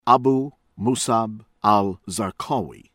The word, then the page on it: Abu Musab Al-Zarqawi: American English